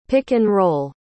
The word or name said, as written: pick-and-roll